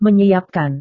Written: menyiapkan

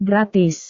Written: gratis

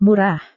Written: murah

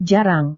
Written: jarang

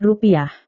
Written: rupiah

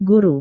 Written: guru

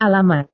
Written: alamat

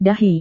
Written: dahi

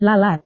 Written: lalat

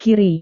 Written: kiri

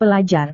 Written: pelajar

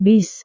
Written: bis

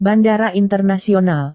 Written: bandara internasional

